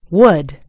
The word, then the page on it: wood: American English